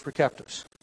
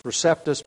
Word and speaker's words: Receptus: Usually pronounced Textus Receptus, but that's really a mispronunciation of the Latin. There is no soft C in the Latin. And so it's Receptus. Textus Receptus would be a better pronunciation.